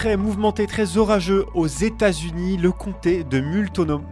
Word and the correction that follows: Multnomah: De de Multnomah, pas évident à prononcer, le comté de Multnomah dans l'Oregon réclame 51 milliards de dollars aux compagnies pétrolières jugées responsables d'un épisode climatique meurtrier en 2021.